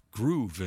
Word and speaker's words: Groove: G-R-O-V-E is pronounced Groove in Italian or Spanish or French or whatever.